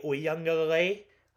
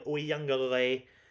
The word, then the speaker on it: Uiagalelei: DJ Uiagalelei, I pronounced that correctly, you might want to save that recording, Uiagalelei, will have a day with NC State, Clemson by two scores.